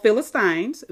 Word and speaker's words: Philistine: Philistines. Those of you who've been following the series, I've said and mentioned in the past episodes that Philistine or Philistine, they are both correct. That is the correct pronunciation.